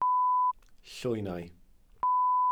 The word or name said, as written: llwynau